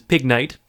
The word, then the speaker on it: Pignite: This is Pignite. With Knight spelled like Pignite. So if you didn't follow that, it's Ignite with a P in front of it to make it a pun.